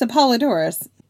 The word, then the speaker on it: Apollodorus: It's Apollodorus, not Apollo. – What did I say? – You said Pseudo Apollodorus. I was like, no, did I spell it wrong? It should be Apollodorus.